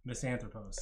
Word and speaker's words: Misanthropos: Okay, that was Misanthropes. – Misanthropos. – Ah, I never say it right.